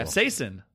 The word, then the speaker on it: capsaicin: I'm seeing here that it is technically pronounced capsaicin. Sin, not shin at the very end.